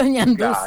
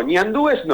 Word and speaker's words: ñanduces: Los ñanduces. – Claro, sí. Así se pronuncia correctamente. Nada de decir... No, ñandúes no, ñanduces.